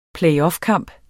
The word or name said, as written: playoffkamp